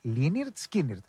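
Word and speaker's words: Lynyrd Skynyrd: αν θα ακούσουμε κάτι ακόμη από Λίναρτ Σκίναρτ, προηγουμένως έκανε καλαμπούρι ο Μιχάλης, το έκανε. Πώς ακριβώς προσφέρονται εκεί στον Νότο? Λίναρτ Σκίναρτ, έτσι προφέρονται.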